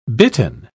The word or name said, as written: bitten